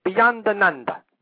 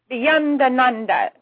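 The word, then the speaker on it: Beyondananda: You make it two separate words, which makes it last longer. – Well, you pronounce it. – Well, it's an Italian name. It's Beyonda Nanda. – Beyonda Nanda.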